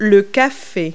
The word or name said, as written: coffee